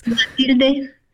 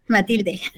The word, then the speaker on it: Matilde: Matilda, I want to ask you. And I'm so sorry. I keep reading your name and knowing that's not how you say your name. So can you please say your name for folks? Matilde. Matilde.